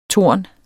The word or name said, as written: torn